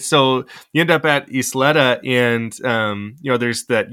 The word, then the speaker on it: Isleta: And I want to make sure I say this right. It's Isleta, right? – Yeah. Yeah. Great job.